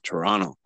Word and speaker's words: Toronto: Well, depending on who you ask, that's, you don't pronounce the second T. It's Toronto.